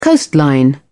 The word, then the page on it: coastline: British English